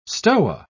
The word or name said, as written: stoa